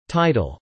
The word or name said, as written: tidal